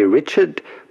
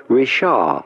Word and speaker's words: Richard: do not pronounce the D to Richard. It's not like in English, you do not say Richard, but rather Richard.